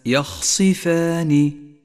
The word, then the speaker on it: يخصفان: وأخيرا اقرأ كلمة يخصفان، يخصفان، ولاحظ أن الياء مرققة والخاء والصاد حرفان مفخمان، والفاء مرققة والألف التي بعدها تتبعها في الترقيق، والنون مرققة أيضا، فلا تخلط في نطقها بين الترقيق والتفخيم، أي هكذا يخصفان، يخصفان.